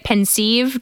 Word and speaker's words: pensieve: So the word pensieve, I always say it pensieve just to, separate it from pensive, the word pensive, but, it probably could also be pronounced pensive, but it's spelled P-E-N-S-I-E-V-E. So I like to say pensieve, but it does come from a combination of two words, pensive, P-E-N-S-I-V-E, and sieve or sieve, S-I-E-V-E.